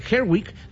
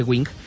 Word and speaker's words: Gerwig: De hecho, Greta Gerwig, lo dije mal hace rato, dije Wing,